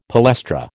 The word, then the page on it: palestra: American English